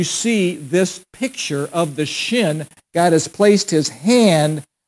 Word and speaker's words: shin: And it means, it's pronounced shin, shin. And it actually, to a Jewish person, is emblematic of El Shaddai, El God, Shaddai Almighty, God Almighty.